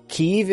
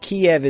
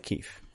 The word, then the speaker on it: Kyiv: Kyiv is the way Ukrainians pronounce it. Kiev is the Russian pronunciation. So call it pronounce the Ukrainian way. – Call it Kyiv.